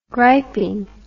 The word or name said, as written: griping